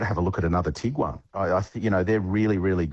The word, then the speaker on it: Tiguan: Afternoon, I'd like to replace my Tiguan with a, is that how you say it?